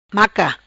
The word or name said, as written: Maca